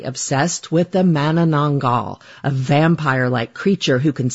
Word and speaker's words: Manananggal: And I got to say, that your pronunciation of Manananggal is quite impressive.